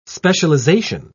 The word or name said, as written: specialization